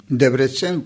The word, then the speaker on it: Debrecen: Donc votre père a une entreprise de confection euh, réputée à Debrecen. Je ne sais pas si on prononce comme ça. – Debrecen, prononcé très bien.